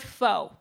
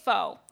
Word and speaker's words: pho: So, a lot of Americans say the word faux because in the English language, the O loses its beautiful squiggly accents that gives it a different sound. So, we say faux.